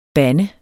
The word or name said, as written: band